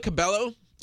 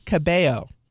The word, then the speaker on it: Cabello: Camila Cabello is performing. – Camila Cabello. – I'm Italian. We don't pronounce two L's as a Y.